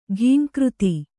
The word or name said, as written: ಘೀಂಕೃತಿ